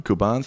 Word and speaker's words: Kuban: Cuban. Is that how I spell it? – Kuban, thank you.